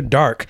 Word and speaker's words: Darque: Well, it's it's, it's spelled D A R Q U E. So I'm thinking dark. – Yeah. – English, you know, English is weird.